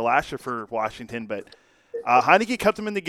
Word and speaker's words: Heinicke: And Matthew Tyler Heinicke is how you say it.